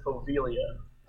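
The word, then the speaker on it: Poveglia: How is it spelled? – Povegilia. – They pronounce it Povelia.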